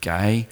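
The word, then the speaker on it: gay (γῆ): It's pronounced in Greek gay, but it's where we get the word geology from, of the soil.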